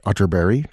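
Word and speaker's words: Atterberg: It's spelled Otterberg, but pronounced Otterberry, just in case you are Googling.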